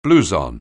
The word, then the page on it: blouson: anglais